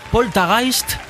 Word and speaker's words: Poltergeist: Es una palabra alemana que realmente, bueno, se pronuncia Poltergeist. – Toma ya. – Y significa, mira, polta, hacer ruidos, y geist, fantasma.